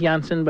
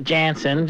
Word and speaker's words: Jansen: Actually, it's pronounced Jansen, but, you know, Canadians call it Jansen, just like...